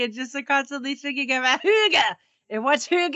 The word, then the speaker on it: hygge: It's called hygge, which is not how you think it would be pronounced when you're looking at it, because it's H-Y-G-G-E, but I guess they pronounce it hygge.